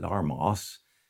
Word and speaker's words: harmos: Fitly joined together is an interesting and complex Greek word, soon armalageo, made from three words soon, meaning together, armos, harmos, H is silent, armos means joint or joining, and lego.